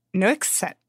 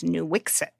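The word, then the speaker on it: Nuiqsut: And it's called Nooksit. This is a hard one. It is Nooksit. I actually have been trying very hard to pronounce it correctly. The official English pronunciation that the state of Alaska has is Nooksit.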